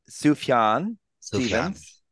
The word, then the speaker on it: Sufjan Stevens: So we're reviewing Sufjan Stevens.